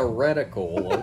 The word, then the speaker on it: reticle: Rectical compensating. – Rectical, okay. – There's a rectumical. – Rectumical. There's another syllable in there somewhere. – It's a reticle, okay, guys?